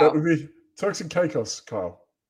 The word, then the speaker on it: Caicos: Apparently, Gator also swam over to Turks and Siakos. – Oh, nice, Caicos. Gator. – I don't know how to pronounce that. Is that Caicos? Caicos. I don't know how to pronounce it. – Turks and Caicos.